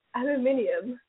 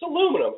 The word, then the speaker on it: aluminum: That's not how you say it. It's not aluminium. It's aluminum. Every other human on Earth calls it aluminum. Except for the fucking British who call it aluminium.